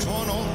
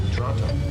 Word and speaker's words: Toronto: I want to welcome out of Toronto, within the traditional territory of the Mississauga people. – Where are we born? – Toronto. – Please don't pronounce the T.